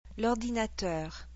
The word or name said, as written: l'ordinateur